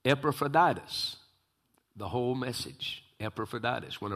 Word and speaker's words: Epaphroditus: And I told him, Epaphroditus. That's how I say it.